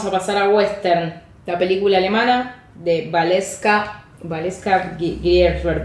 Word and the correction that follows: Grisebach: estoy pronunciando mal el apellido. La vieron 1500 personas. Hoy estoy muy con la cifra al pedo, ¿no? Pero para ver como la diferencia. – Valeska Grisebach. – Grisebach.